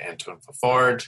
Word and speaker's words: Antoine Fafard: It is Chemical Reactions, Gavin Harrison and Antoine Fafard.